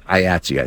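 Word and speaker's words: IATSE: IOTSI, I think, is actually how it's pronounced.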